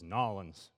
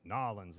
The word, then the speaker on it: New Orleans: And this is Nollins, right? New Orleans. Nollins, they say over there.